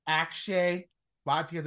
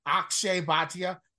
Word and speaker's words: Akshay Bhatia: I went Will Gordon 66-1, MJ Duffy 75-1, Akshay Bhatia. Did I say it right this time? – I said it better this time. There you go. My bad. Akshay Bhatia 90-1 and Brent Grant 175-1, all four of them with five places each way.